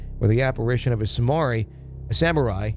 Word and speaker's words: samurai: I always say that incorrectly. – Samari. That's funny. – That's like my fourth grade version of me coming out, because there was a video game called Samurai Showdown, but I didn't know how to pronounce it in fourth grade, so I just called it Samari.